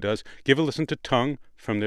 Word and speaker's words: Tunng: Tongue is their name, spelled T-U-N-N-G.